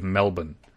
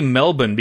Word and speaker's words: Melbourne: I think Australians are douchebags for insisting on the pronunciation of Melbourne. Because it's only Melbourne because that's how their accent pronounces the second syllable.